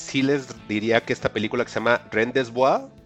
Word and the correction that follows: rendezvous: se escribe Rendes con Z y luego B-O-U-S, como si fuera en francés, algo ahí raro. rendezvous. Ah, ah, muchas gracias, Juan.